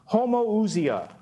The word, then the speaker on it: homoousia: The word is homoousia.